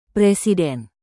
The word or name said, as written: presiden